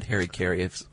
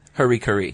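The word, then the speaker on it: harakiri: I always said Harry Carey. – Bushido. Harry Carey is gut cut. – No. – Oh, Harikari? – Harikari. – Yeah.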